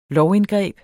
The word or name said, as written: lovindgreb